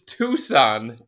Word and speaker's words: Tucson: Dave and Tuxin ask. – Hold on. Did you just say Tuxin? – Yeah, what? It's T-U-C-S-O-A-N. – It's Tucson. Tucson, Arizona.